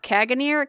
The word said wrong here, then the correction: Caganer: Caganeer. I'm not cool. Caganer.